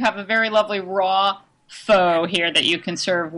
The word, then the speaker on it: pho: Pho, which I pronounce pho, but I've learned it's pronounced pho.